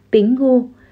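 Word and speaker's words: Bình Ngô (苹果): Trong tiếng Trung, quả táo được phát âm là Bình Ngô, đồng âm với chữ Bình trong chữ đêm bình an.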